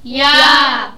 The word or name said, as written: ja